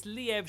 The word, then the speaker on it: Liev: Can I just say, it's Liev Schreiber. You've both been saying his name wrong the whole episode. – I know, but that's such an effort, you know? How dare he squeeze an extra syllable into his name? – Sorry, Liev.